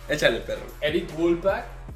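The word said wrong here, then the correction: Wolpaw: Wolpau, perdón, no lo pronuncié bien. Eric Wolpau.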